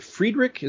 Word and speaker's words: Friedrich: In 1971, he and Esposito did the art for both issues of writer Gary, is it Friedrich? Is that how you pronounce his name? – That's how I pronounce it. I don't know if that's how he pronounces it.